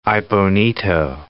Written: Aibonito

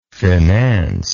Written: financeable

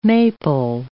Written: maple

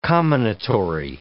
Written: comminatory

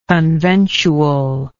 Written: conventual

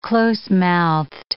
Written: closemouthed

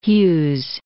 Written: Hughes

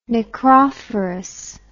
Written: necrophorous

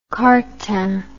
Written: cartan

